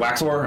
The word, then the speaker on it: Waxor: And is it Waxor? Am I saying it right? – Yes.